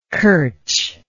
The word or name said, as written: curch